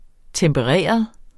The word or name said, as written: tempereret